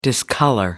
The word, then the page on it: discolor: British English